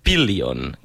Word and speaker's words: Pillion: Bé, si no corregeix-me, però és Pillion, que es pot veure a Movistar Pillion. Plus. Això, correcte.